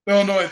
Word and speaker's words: Illinois: Illinois, the S is silent, buddy.